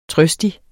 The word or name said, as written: trøstig